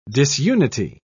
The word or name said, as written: disunity